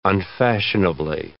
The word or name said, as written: unfashionably